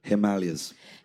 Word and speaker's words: Himalayas: Himalayas.